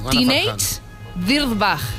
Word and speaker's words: Teenage Dirtbag: Bueno, es un reto viral muy bien llamado Teenage Dirtybag. – Ah, qué bonito. – No, espera, no lo he dicho bien. ¿Lo ves? – Ha dicho no. – Teenage Dirtbag.